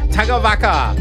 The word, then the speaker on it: Tagavaka: Have you worked out how to pronounce the artist? – Yes. – Go on. – Is it Tava something Noski? Tava, yeah. What is it? Yeah. – Tagavaka. – Tagavaka, that's right.